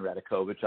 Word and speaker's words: Radakovich: and they bring in somebody like a Dan Radakovich, and I hope I didn't butcher his name. – No, you said his name right. I would be a big proponent of Dan Radakovich.